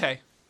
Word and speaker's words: Hay: Is it Colin Hay? – Yeah, Colin Hayes, I think. – No, just Hay.